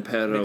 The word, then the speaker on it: perro: And then perro is pet.